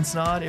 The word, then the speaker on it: Snaadt: My name is Ryan Snod, A Rhymes with Odd, and we'll see you in the next episode.